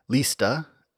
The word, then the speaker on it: liste: This form also derives from an Old English word, lista, L-I-S-T-E, with a long I, meaning a fringe or border.